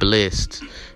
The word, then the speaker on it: Blxst: And my nigga Bless, you might not know who it is by the name, his name Bless. I just found out that's how you say the nigga name. It's spelled BLXST, Blessed.